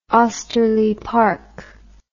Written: osterly park

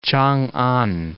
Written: Changan

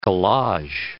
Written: collage

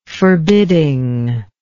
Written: forbidding

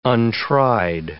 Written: untried

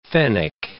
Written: fennec

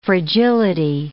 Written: fragility

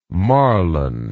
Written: Marlon

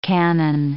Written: canon

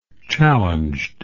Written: challenged

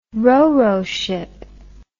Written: roro ship

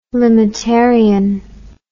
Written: limitarian

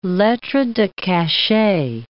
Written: lettre de cachet

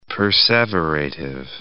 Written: perseverative

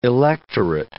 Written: electorate

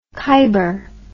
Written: Khyber

